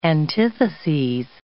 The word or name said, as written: antitheses